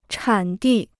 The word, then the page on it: 产地: chǎn dì